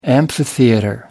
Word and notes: amphitheater: British English

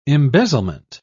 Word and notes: embezzlement: British English

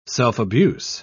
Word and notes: self-abuse: British English

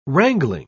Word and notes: wrangling: British English

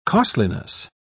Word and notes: costliness: British English

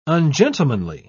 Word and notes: ungentlemanly: British English

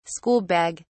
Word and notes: school bag: British English

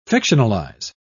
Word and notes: fictionalise: British English

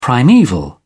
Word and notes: primaeval: British English

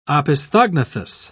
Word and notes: opisthognathous: British English

